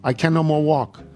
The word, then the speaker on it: Oconomowoc: Halfway through, he abruptly stopped, sat on a log, and pronounced, I can no more walk it. Oh, I can no more walk. That's how it's pronounced.